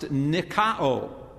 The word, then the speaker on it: nikao: The Greek verb is pronounced nikao.